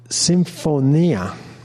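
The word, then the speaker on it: Symphonia (συμφωνία): Symphonia in Greek is how it's pronounced.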